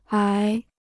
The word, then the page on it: 癌: ái